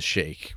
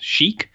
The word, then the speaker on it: Sheik: I think it's pronounced chic, but it's spelled S-H-E-I-K. So yes.